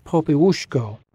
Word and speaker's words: Popiełuszko: His last name is pronounced Popiełuszko, and he hadn't had a very distinguished ecclesiastical career up to that point.